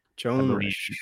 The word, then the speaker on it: Joan Risch: Joan Risch. – Pronounce that. – Yep.